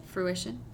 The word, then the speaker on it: fruition: And it was my story for How do you pronounce the word? – What? – Fruitation? – Fruition. – Fruition? I said fruitation.